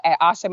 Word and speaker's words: Asha: But you can find me at Asha Mayar on Facebook and Dreams to Destiny on Instagram.